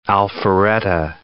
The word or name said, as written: Alpharetta